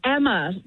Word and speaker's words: Emma: Daughter, it's Emma. Yeah, pronounced like hammer, but no H.